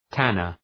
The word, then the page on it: tanner: ‘tænər